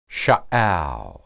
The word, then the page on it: shaal: hebrew